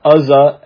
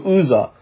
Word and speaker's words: Uzzah: I believe it's not Uzzah as in a short U, but a long U, Uzzah.